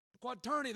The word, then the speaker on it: quaternity: Not only just the two on either side of Peter, but There's that big Q word there, Brother Harold said, that I'm from Oklahoma and can't pronounce. Quaternity there, thank you.